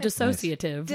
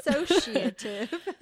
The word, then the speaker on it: dissociative: Tell me about disassociative. Did I say it right? – Or sort of dissociative. I think you might have added a syllable, but I like it. – Dissociative.